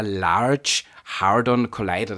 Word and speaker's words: Large Hadron Collider: der Large Hadron Collider, das habe ich mir aufgeschrieben, habe ich es richtig ausgesprochen? – Absolut.